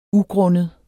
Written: ugrundet

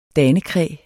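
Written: danekræ

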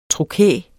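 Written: trokæer

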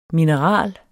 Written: mineral

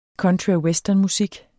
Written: country

